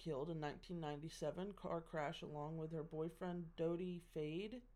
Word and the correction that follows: Fayed: Fayed, Fayed.